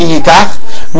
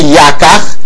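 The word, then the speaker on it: Yakach (יקח): Says the Gemara, Hachanami, because we have this question regarding Shtar Kedushin, don't pronounce the word Ki Yikach, rather pronounce it Ki Yakach,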